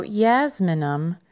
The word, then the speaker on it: Jasminum: So, jasminum, yes, I pronounced that like a Y, but it's actually spelled with a J.